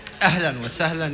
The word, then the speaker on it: Ahlan wa sahlan: Ahlan wa sahlan ya Yahudi.